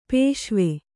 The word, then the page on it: ಪಸಿವು: kannada; pasivu